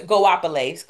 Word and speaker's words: Goapele: One is, I never know how to say her name. Goapele. – Goapele. – Closer. Goapele. Closer.